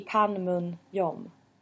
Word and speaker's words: Panmunjom: I Panmunjom. – Jag gillar ditt uttal ändå alltså. Känns ändå stabilt.